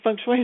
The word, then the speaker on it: feng shui: Am I pronouncing it right? – I know. Feng shui. Sometimes it's called feng shui, like depending on the dialect.